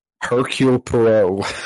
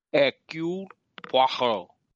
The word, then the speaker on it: Hercule Poirot: Hercule Poirot. – There you go. – Yeah, Poirot. – Dude, don't look at me. I'm not cultured. – Hercule Poirot.